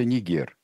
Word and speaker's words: Нигер: Правильное ударение в названии страны, где бы что ни писалось, это Нигер.